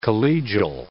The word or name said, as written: collegial